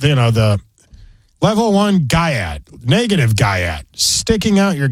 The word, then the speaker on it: Gyat: G-Y-A-T. – Oh, I was thinking Gat, like in, you know, are you strapped, you know? – But no, it's not.